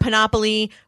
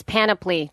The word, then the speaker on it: Panoply: So basically what this is all the big boy networks, NPR, Panoply, Wondery, Time, Podcast One. – Dude, it's Panoply. Just fuck, I say that wrong every time.